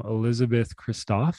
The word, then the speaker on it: Elisabeth Kristof: So welcome, Elisabeth Kristof.